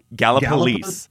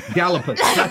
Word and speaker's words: Gallipolis: All right, it's pronounced Gallipolis. – Gallipolis. I knew because, that, like, because when I was there, I was pronouncing it wrong, and they all corrected me.